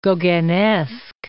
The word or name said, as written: Gauguinesque